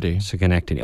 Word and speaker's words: Schenectady: I think she was from Schenectady, New York, if you how you pronounce that. – Schenectady. – Okay, I was way off.